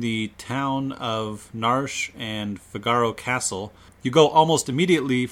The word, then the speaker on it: Figaro: Yes, we pronounce it Figaro, not Figaro. I've heard it pronounced that way. We're not going to do that. But it's fine if you do.